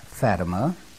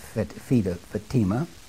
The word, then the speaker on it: Fatima: In 1917, on May the 13th, three little children in Fatima, and by the way, some pronounce it Fatima, but I shall pronounce it Fatima, they said that on October the 13th, and they gave the month, they gave the day, and they gave the precise hour.